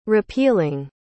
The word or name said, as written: repealing